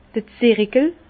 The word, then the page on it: d'r Zirikel: Bas-Rhin; Herrlisheim